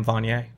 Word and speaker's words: Vaughne: So in England, we would pronounce that Vaughne. – Yeah. – Like a, actually with an E, I'm not sure.